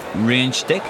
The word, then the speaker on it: renstek: Renstek sounds pretty good to me. Did I say that right? – Very good. – Renstek, is that correct? Yes.